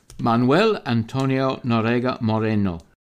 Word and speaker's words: Manuel Antonio Noriega Moreno: Hugo Rafael Chavez and Manuel Antonio Noriega Moreno. – Your Spanish pronunciation is fantastic.